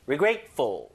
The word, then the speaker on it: regretful: Regretful, regretful, 뭐 grateful, thankful, thoughtful 이런 거 있듯이 regretful, regretful, regretful. I feel regretful. 아, 좀 후회스러워. 그죠? 후회스러워. I feel regretful. Regretful. 강세 잘 보세요. Regretful.